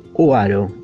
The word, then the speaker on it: Oaro: Se escribe A-N-N, Anne Oaro, O-A-R-O, Anne Oaro, desde Isla de Reunión, en este planeta folk.